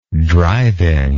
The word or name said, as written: drivingly